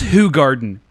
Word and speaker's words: Hoegaarden: Somebody was like, it's Hoegaarden. Please pronounce it correctly.